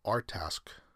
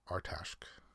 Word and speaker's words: Artsakh: The Republic of Artashk, Artashk, a de facto state has been established in the latter. Okay. So there's art it's spelled A R T S A K H.